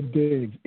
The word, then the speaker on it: Diggs: Nail Diggs, N-A-apostrophe-I-L, last name Diggs, D-I-G-G-S.